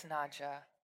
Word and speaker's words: Nadja: Do we pronounce it Nadja or Nadia? How do people pronounce it? – I believe it's Nadja.